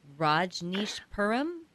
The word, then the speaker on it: Rajneeshpuram: how do you pronounce this? Rajneeshpuram? – Yep, you got it.